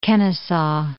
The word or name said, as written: Kennesaw Mountain